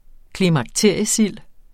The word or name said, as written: klimakteriesild